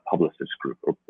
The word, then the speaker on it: Publicis: Or how would we say in French? What would be a good pronunciation for that? – It would be right. It would be Publicis Group. – Publicis. Okay, maybe we'll change the U just a little bit, but kudos to the French for allowing us to pronounce their name.